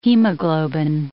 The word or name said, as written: hemoglobin